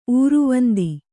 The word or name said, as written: ಊರ್ವಂದಿ